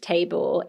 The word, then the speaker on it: Tabor: So Tabor, which they have as their middle name, is spelled T-A-B-O-R. – Okay, so I said that wrong. – Well, it's really nice. It could be Tabor. I like Tabor as well, actually.